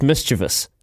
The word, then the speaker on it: mischievous: He can't even pronounce the word properly. It's mischievous.